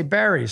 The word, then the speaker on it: Wilkes-Barre: Friday Night SmackDown is in Wilkes-Barre, Pennsylvania. Yeah, Wilkes-Barre. I've heard it pronounced both ways. – It's B-A-R-R-E, and they say Barry sometimes, huh? – Sometimes. It depends who you talk to.